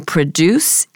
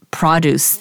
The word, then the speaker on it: produce: Friends, please note the difference in pronunciation. You produce, and then this is produce, the noun.